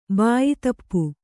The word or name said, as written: ಬಾಯಿತಪ್ಪು